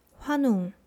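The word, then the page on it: 환웅: Hwanung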